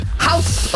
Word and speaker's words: Haus: So it's spelled H-A-U-S, isn't it? – Yeah. – House of Condiments.